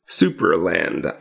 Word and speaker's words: Supraland: I always feel I need to spell it because I feel like people are going to hear Supraland. – Yeah, Supraland. – Supraland, which is S-U-P-R-A land. All one word.